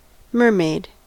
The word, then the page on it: mermaid: American English